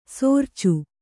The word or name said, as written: ಸಾರ್ಚು